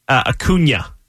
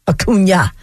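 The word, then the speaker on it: Acuña: How do you say Ronald's last name? Acuna, or is there like some... – Acuna. – Acuna. – Yes. – I saw the little tilde.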